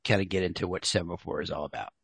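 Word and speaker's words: Semafor: Am I pronouncing that correctly? – Perfectly. – Okay, cool. Sometimes people are like, it looks one way, but it's like, no, it's not Semaphore, it's Semaphore.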